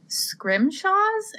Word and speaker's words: scrimshaw: So, uh, to hop back to the collection at PPL a little bit, I also saw on the website about these scrimshaws. Am I saying that right? – Yeah,